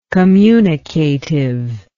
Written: communicativeness

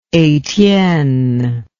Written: Etienne

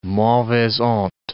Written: mauvaise honte

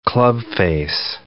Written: clubface

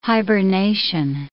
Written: hibernation

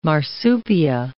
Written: marsupia

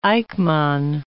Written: Eijkman

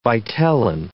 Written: vitelline

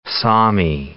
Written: Sami

